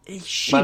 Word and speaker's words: Cima: Shima.